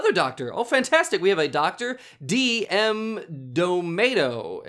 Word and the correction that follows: D'Amato: It's D'Amato, but we'll let it fly for right now. – Dr. D.M. D'Amato.